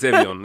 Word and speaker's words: Evian: Yeah, we're not doing French pronunciation. It's Evian.